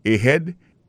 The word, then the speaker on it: ahead: Ahead. Ahead là, c'est A-H-E-A-D. Ahead. Ahead. Go ahead. Là encore, on a vu que accent tonique là tombé sous deuxième syllabe.